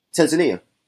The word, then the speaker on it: Tanzania: Tanzania. Some people say like tomato, tomato. I say Tanzania. Yeah. Tanzania. Some people say locals say it that way. Americans say Tanzania.